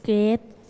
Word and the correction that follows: gray: Gray, it's gray.